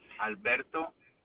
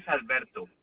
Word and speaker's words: Alberto: Alberto Avila. – Is that your first name? – No, my first name is Alberto. – How do you spell that? – A-L-B-E-R-T-O. Alberto. – And how do you spell the last name? – A-V-I-L-A.